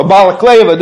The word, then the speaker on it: Balaklava: On this one particular battle, on October 25th, 1854, called the Battle of Baklava, or Balaklava, depending on how you want to pronounce that, has often been told and retold for its inept handling by the Allied forces.